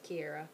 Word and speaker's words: Kiera: So they know my name is pronounced Kiera, and then just Desai, which I feel like that one's a little bit more simple.